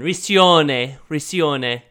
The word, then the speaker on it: Riccione: Challenge Raccoon. – You just got to say it like you're Italian. Riccione.